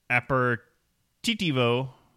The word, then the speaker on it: aperitivo: It is pronounced aperitif in French and aperitivo in Italy.